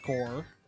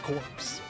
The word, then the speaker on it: Corps: I mean, like, I learned the correct pronunciation of, you know, CORPS from Blast Core. Because, you know, if you say it like, you know, you would spell it if you didn't know what that word meant, or, you know, you'd never heard it spoken out loud before. I mean, you would think it's Blast Corpse, which sounds like super violent.